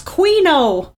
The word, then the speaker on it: quinoa: And Joe said, it's pronounced Queen-O, when we were talking about quinoa last week. And Tony saying quinoa. According to this commercial, it's Queen-O.